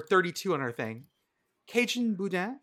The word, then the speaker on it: boudin: Did I say it right, Boudin? – It's Boudin, but yes. – Boudin? Boudin. Oh, did I put the emphasis on the wrong syllable? – Yeah, Boudin?